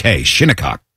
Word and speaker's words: Shinnecock: Shinnecock.